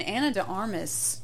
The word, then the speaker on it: Ana de Armas: And Ana de Armas, did I say her name correctly? – Yeah, yeah.